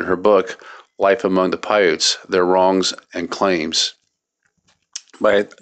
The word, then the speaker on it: Paiutes: I think it's pronounced Paiutes, P-A-I-U-T-E-S.